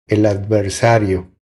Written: El adversario

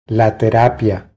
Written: La terapia

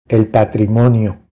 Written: El patrimonio

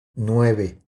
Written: Nueve

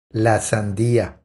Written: La sandia

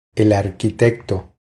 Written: El arquitecto

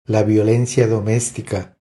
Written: La violencia domestica

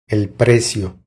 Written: El precio

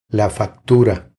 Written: La factura

